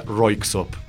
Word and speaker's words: Røyksopp: Se escribe de una manera extraña porque son, creo que, noruegos o de algún país escandinavo, eso se los puedo asegurar, pero el nombre se pronuncia Røyksopp.